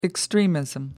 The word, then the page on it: extremism: American English